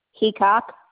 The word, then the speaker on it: Heacock: Karen Heacock.